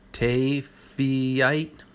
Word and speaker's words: Taaffeite: Now, Tafinite, something like that. We've talked about this one too, $15,000, one of the rarest gemstones. Only 50 are known to exist. It's spelled T-A-A-F-F-E-I-T-E, Tafeite.